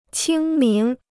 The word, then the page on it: 清明: qīng míng